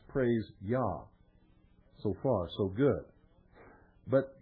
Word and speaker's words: Jah: Yah, J-A-H, the first syllable, represents the name Yahweh, or Jehovah, the name of the God of Abraham, Isaac, and Jacob of the Bible.